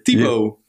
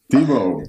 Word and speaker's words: Thibault: Hoe spreek je jouw naam uit? – Ja, zo'n dingetje. – Thibaut. Thibaut. Ja, dat is eigenlijk een Franse naam, ja.